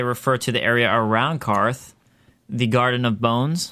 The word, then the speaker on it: Qarth: Yeah. Not Qarth. – Not Qarth. – Not Qarth, but Qarth.